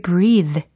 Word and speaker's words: breathe: 오, 이 문장 중에서는 hear you breathe의 그 breathe 발음이 중요합니다. 왜냐하면 어, here 지각동사 들렸죠? you 목적어 들렸죠? 목적 보어 자리에 동사 원형인 거예요. breathe 호흡하다, 숨쉬다 라는 거죠. 이게 동사가 아니고 명사로 읽는다면 breath가 되겠죠.